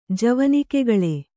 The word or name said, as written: ಜವನಿಕೆಗಳೆ